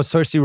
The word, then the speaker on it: Saoirse: Saoirse. – Oh, Saoirse Ronan.